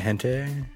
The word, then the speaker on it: hentai: T-A-I. – Isn't that how it's pronounced? I don't know. – Yeah, Japanese is pronounced exactly as it's spelled. – So hentai. – That's if it's T-A-I.